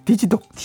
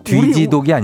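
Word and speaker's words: Digidog: 디지독. 디지독이요? 어, 발음 잘하죠. 디지독. 아, 디지독, 디, 디지털 할때 디지. 그렇죠. 그러니까 뒤지독이 아니죠. 그러니까 우리식 발음으로 하면 안 돼요.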